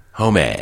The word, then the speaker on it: Homme: Is that how you say it? – Yeah. Homme. – It's Josh Homme? – Uh-huh. Hey, Homme. – It's not Josh Homme, huh? – No, Homme.